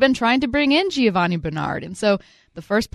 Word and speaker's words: Giovanni: Curtis, do you want to go ahead and hit me with the Italian pronunciation? – Giovanni. – Exactly right.